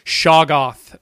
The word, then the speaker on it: Shoggoth: The Shagoth, or the Sogoth, or whatever. What's it called? How do you pronounce it? Shoggoth.